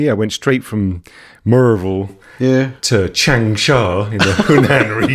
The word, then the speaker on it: Maryville: You know, I mean, I remember I was playing in, like, at the Kaufman camp in Maryville, Tennessee, pronounced Merville.